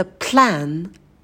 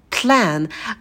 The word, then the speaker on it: plan: Следующее слово – это plan. Plan, когда произносите «п», в этом слове, то уже на «п» язык ставьте на альвеолы и выдыхайте воздух. Plan, plan. И не забывайте про звук лягушку «э».